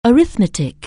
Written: arithmetic

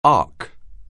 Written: ark

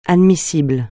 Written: admissible

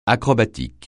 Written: acrobatique

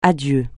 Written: adieu

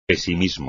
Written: pesimismo

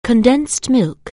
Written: condensed milk

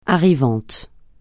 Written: arrivante

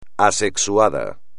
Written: asexuado

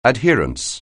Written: adherence

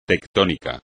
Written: tectónico

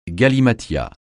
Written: galimatias

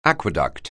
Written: aqueduct

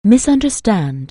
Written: misunderstand

